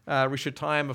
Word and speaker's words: Rishathaim: Rishathaim, of course, rhymes with Aram-Naharaim, the place from where he came in Mesopotamia, between the Tigris and Euphrates.